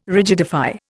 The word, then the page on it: rigidify: Indian